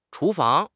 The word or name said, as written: chu2 fang2